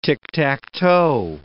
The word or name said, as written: tick-tack-toe